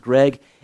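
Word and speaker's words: Greig: It's G-R-E-I-G-G, I think, but I think it's pronounced Gregg.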